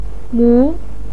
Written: mu2

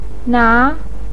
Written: na2

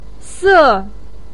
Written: se4

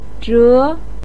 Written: zhe2

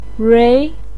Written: rui2

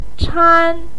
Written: chan1

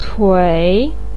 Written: cui3

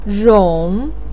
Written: rong3

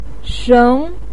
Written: sheng2

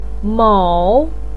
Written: mo3

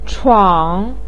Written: chuang3

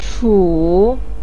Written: chu3

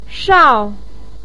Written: shao4

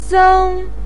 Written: seng1

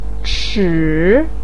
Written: chi3

